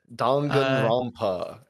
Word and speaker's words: Danganronpa: Yeah, Danganronpa.